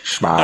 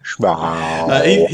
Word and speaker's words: Smaug: And I think it was Tolkien who, when he was writing, and he was talking about Smaug or Smaug or however. – Smaug. – I don't know how to pronounce that. – Smaug.